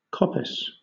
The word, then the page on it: coppice: British English